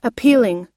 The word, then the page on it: appealing: long-i